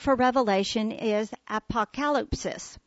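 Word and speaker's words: apokalypsis (ἀποκάλυψις): Although the noun is pronounced apokalypsis, the verb is pronounced apokalypto.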